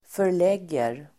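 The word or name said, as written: förlägger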